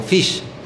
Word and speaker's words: Phish: Hay un grupo que se llama Fish, no se confundan con Fish. Uh-huh. Los dos se pronuncian idéntico. Uno es F-H-I-S-H y el otro es P-H-I-S-H.